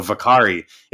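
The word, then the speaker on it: Whakaari: And because it's Maori, it's the W, it's not a W, it's a V. Like WH, I guess, is a V sound. So Vakari is what I kept hearing from all the Kiwis in the documentary. So I think that's how you say it.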